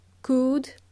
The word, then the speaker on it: could: There is more modal verbs, such as may, might, could. L is silent. The same as should, L is silent. We spell could with an L, but we do not pronounce L. We do not pronounce the letter L.